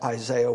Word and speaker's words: Isaiah: The name Isaiah is probably more properly pronounced Isaiah, but the name Isaiah has that Yah sound at the end of it in the Hebrew language.